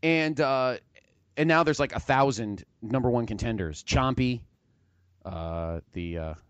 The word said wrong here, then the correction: Tommaso Ciampa: Is it Chompy? Is that pronounced correctly? – Chompy's that big black thing from the Mario game. – No, that's Moose. Oh, I like Chompy better. – Champa, Tommaso Ciampa. – Tommaso Ciampa. Thank you.